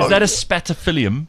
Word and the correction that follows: Spathiphyllum: Spathiphyllum, yes.